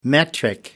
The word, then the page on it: metric: American English